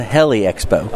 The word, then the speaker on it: Heli Expo: We are your crew today for Just Plane Radio, and we are at the Helio Expo. Right? Did I say it right? – No, as usual, you screwed it up. – Well, what did he say? – It's the Heli Expo.